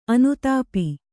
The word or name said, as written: ಅನುತಾಪ